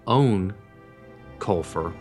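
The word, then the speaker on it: Eoin Colfer: The author, name pronounced actually Eoin Coifler, but it looks like Ian, hard to say, Eoin Coifler, has just released a brand new series called Warp.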